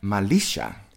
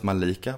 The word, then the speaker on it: Malisha: It's Jonida, and I actually learned how you pronounce her last name today. It's Jonida Malisha. – Malisha. – I thought it was Malika, but there you go.